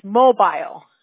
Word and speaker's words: Mobile: I always say mobile Alabama, right? She's like, no, it's mobile. – Mobile, right? She always corrects me because I always say it wrong.